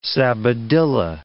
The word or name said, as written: sabadilla